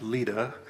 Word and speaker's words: Lydda: And since Lydda, it's pronounced Lydda,